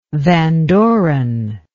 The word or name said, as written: van doren